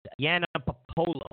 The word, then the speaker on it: Yiannopoulos: It's Yiannopoulos. Milo Yiannopoulos. Y-I-A-N-N-O-P-O-U-L-O-S. I always say it wrong.